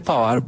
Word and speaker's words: Pavard: Ahorita que pronunciaste Pavard, ¿vos dónde aprendiste a pronunciar tan bien el francés? No es fácil.